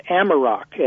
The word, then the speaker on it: Amarok: But the one that I recommend to most people is something called Amarok, and it's spelled A-M-A-R-O-K.